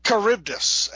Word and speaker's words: Charybdis: But he gave us a story in which Aquaman loses his hand to how do you pronounce the enemy's name? The villain? – Charybdis.